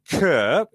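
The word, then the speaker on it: Chur: I mentioned the Big Air in Kerr, which is the correct pronunciation.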